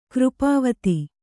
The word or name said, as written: ಕೃಪಾವತಿ